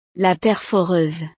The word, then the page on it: la perforeuse: frances